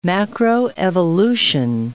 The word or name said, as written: macroevolution